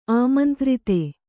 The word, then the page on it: ಆಮಂತ್ರಿತೆ: āmantrite